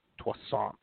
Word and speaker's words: Toussaint: Toussaint. He's a croissant.